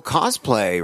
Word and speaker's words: cosplay: Well, cosplay, right? Is that how you pronounce that? – Yeah. Yeah.